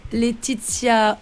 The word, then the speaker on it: Letizia: C'était Letizia, bien sûr.